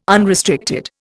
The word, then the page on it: unrestricted: Indian